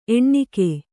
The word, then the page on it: ಎಣ್ಣಿಗ: eṇṇiga